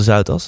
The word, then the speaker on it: Zuidas: Bezinning op de Zuidas, zeg ik het goed? – Ja.